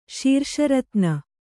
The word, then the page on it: ಶೀರ್ಷರತ್ನ: kannada